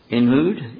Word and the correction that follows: Ehud: Ehud, and Shamgar, Shamgar.